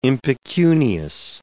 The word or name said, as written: impecunious